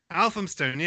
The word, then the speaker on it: Elphinstone: Am I pronouncing that correctly? – Elphinstone, yes.